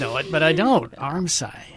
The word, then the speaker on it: armscye: So, I'm going to spell it first because it's spelled A-R-M-S-C-Y-E. But it's pronounced Armsye.